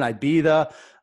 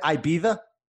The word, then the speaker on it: Ibiza: He might be over there in Ibiza, a few of those other places. So he's what'd you call it? – Isn't it Ibiza? – Oh, yeah, Ibiza, yeah. Ibiza, so. Ibiza.